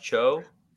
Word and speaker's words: Cho: So yeah, my name is Josh Cho, rhymes with ho, not how.